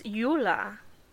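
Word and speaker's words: Juhla: That was Yula.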